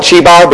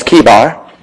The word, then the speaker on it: Chebar: Throughout the time of preaching, I might call it Chebar because that's what I've always called it. But the correct pronunciation is Chebar.